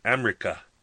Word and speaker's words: Amrika: They try to build up our lives in America, spelled A-M-R-I-K-A, America. Ashraf God says, using the common pronunciation for America in the community.